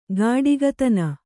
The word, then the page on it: ಗಾಡಿಗತನ: gāḍigatana